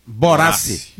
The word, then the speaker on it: Borassi: Como é a pronúncia? – Borassi.